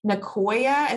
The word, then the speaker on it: Nicoya: Is it Nicoya? Is that how you pronounce it? – Yeah, that's right.